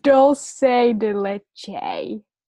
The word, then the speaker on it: dulce de leche: Dol dulce dulce de leche.